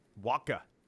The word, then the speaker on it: Wacha: Waka.